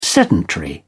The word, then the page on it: sedentary: British English